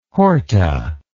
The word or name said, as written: horta